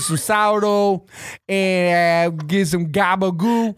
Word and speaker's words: gabagool: It's gobagoo. – No. – Gava gava. What the fuck is gava gava? – How do you say it? – Not a turkey. It's gabagool.